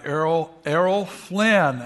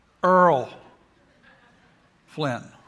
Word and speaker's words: Errol: And this guy, Errol Flynn, and growing up in South Carolina, we pronounced it Earl Flynn.